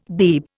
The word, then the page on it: Beeb: Indian